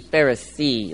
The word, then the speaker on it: Pharisee: Pharisee, P-H-A-R-I-S-S-E-E, Pharisee. That's how you say it.